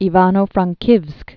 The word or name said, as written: Ivano-Frankovsk